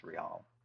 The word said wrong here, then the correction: Real: It's a Benjamin Real. – Real.